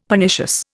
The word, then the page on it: Pernicious: Indian